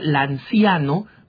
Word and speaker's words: Lanciano: de la palabra lanciano, con C de casa, Lanciano, se pronuncia en italiano, van a encontrar muchas explicaciones absolutamente científicas.